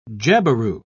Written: jabiru

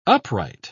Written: upright

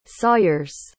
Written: sawyers

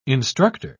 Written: instructor

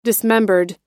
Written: dismembered

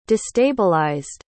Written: destabilized

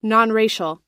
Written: nonracial

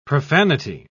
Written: profanity

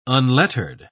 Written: unlettered

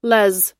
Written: les

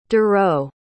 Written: dareau

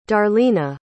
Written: darlena

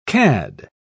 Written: cad

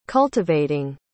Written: cultivating

